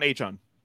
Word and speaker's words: Achane: Devon Achane. – Achane.